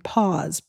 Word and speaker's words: PAWS: And she uses the acronym PAWS, P-A-W-S. And you know, it rhymes with pause, like to take a break.